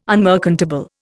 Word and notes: unmerchantable: Indian

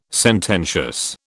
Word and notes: sententious: British English

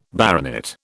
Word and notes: baronet: British English

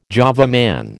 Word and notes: Java Man: American English

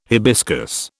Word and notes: hibiscus: British English

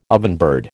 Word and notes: ovenbird: American English

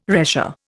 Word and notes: retia: Indian

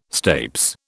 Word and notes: stapes: British English

